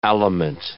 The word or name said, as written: aliment